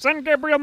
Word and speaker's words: San Gabriel: Sunset over the San Gabriel Mountains. – It can be. – It's pronounced San Gabriel Mountains.